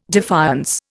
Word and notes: defiance: Indian

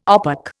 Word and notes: OPEC: Indian

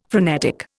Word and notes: frenetic: Indian

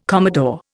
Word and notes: commodore: Indian

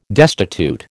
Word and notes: destitute: American English

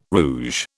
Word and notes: rouge: British English

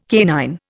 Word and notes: canine: Indian

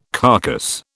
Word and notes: carcass: British English

